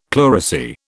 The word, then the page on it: pleurisy: British English